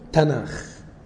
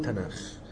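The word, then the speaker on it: تناخ (תנ״ך): يبقى الاسم هم بينطقوه تناخ، النطق العبري بينطقوه تناخ من التاه والنون والكاف، والكاف ده ساعات بيتقلب نطقه على خاء.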